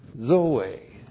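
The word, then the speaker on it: Zoe (ζωή): The other part of the couplet is Zoe. Zoe. Z, long O, long E. Zoe.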